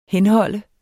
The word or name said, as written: henhold